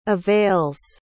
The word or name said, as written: avails